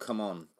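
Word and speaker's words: CMON: They've recently changed how you pronounce the name. – Go on, tell me, because I don't know about this. – They now want to be called Come On.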